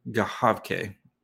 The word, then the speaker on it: Gawanke: Leon Gahavke. Yes. So it's spelled Gawanke, but it's Gahavke because he's German.